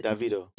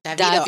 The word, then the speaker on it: Davido: How do you say, I call him Davido. How is Davido. – Davido. I just learned that.